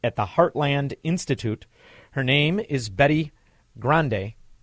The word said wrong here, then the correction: Grande: Betty Grandy, thank you so much for joining me.